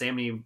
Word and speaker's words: Samnee: Um, the other one on so on my like honorable mention list uh, is and I just mentioned this the the Chris Samnee uh, Mark Waid Black Widow run, mm-hmm. – which was basically excuse me, like, it's the uh, oh no, you did say it right.